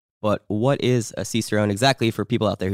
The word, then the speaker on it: Cicerone: Oh, and more importantly, certified Cicerone. Did I say that right? – Cicerone, yeah. – Fuck. – Everyone, no one says it right.